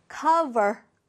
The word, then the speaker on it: cover: 자, V의 발음을 살려서 발음해보세요. cover. cover 아닙니다. b 아니고 v니까요.